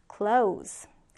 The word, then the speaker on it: close: 자, 동사일 때는 우리가 이렇게 Z 발음을 끝내요. 그래서 close. close. 네, 이렇게 발음이 되죠. 동사는 눈을 감다 또는 문등을 닫다라는 의미가 돼요. close. 자, 그러면 형용사 볼게요. 형용사. 형용사는 S 발음으로 끝나죠.